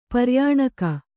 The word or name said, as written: ಪರ್ಯಾಣಕ